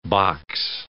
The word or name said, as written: box